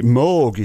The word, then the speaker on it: Moog: Well, actually, Moog is how it's pronounced, but it's spelt Moog.